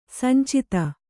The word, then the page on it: ಸಂಚಿತ: sancita